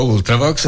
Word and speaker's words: Ultravox: Allora, messaggio di Marco, io presentando gli Ultravox, l'ho pronunciato all'inglese con la short U iniziale, e Marco dice: veramente Ultravox è una parola latina.